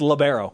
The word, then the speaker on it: libero: And it's libero. We determined that last time you were on the show, the proper pronunciation. – Our Italian stat guys claims that the libero... – Giuseppe. – Libero word started in Italy, so it's actually libero.